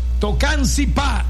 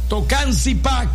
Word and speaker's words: Tocancipá: Tocancipá, acento en la última A, Colombia. Tocancipá, Colombia.